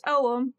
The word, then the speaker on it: Ogham: So it's spelled O-G-H-A-M, but it's pronounced Oum because Celtic.